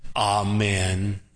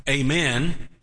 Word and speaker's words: amen: Now, don't say it like a Yankee. I love picking on our Yankees. Amen. Come on, friend. You're way below the Mason-Dixon line down here. You got to say it either like a, you can say it like a Southerner, amen, or you can say it like a Georgian if you really want to get religious here, amen.